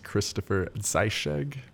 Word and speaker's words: Christopher Zeischegg: My name is Christopher Zeischegg.